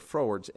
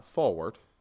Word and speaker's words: froward: That word froward's interesting. It's not forward, it's froward. It's spelled differently than forward.